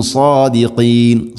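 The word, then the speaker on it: صادقين: ഇൻ കുന്തും സാദിഖീൻ, സാദിഖീൻ, സാദിൻ്റെ ഉച്ചാരണം ശ്രദ്ധിക്കണം. രണ്ട് ചുണ്ടുകൾ തമ്മിൽ കൂട്ടി ഇങ്ങനെ റൗണ്ട് ഷേപ്പിൽ വരുന്ന രൂപത്തിലല്ല, ചുണ്ടുകൾക്ക് പ്രത്യേകിച്ച് അവിടെ വർക്കില്ല. സാദിഖീൻ, സാദ് ഹെവി ലെറ്ററാണ്, അഥവാ ഇസ്തിഇലാന്റെ അക്ഷരമാണ്, സ്വരഭാരത്തിൽ ഉച്ചരിക്കണം. സ, സ, നാവിൻ്റെ മധ്യഭാഗം അണ്ണാക്കിലേക്ക് ഉയർന്നു നിൽക്കണം.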